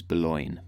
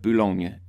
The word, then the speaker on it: Boulogne: Now, I probably have completely massacred the pronunciation of that place name, but I am completely dedicated to doing better than what the Americans call it, which is Boulogne, which is, I suppose, a bit like baloney. It's spelt similarly, and I think that's where that comes from.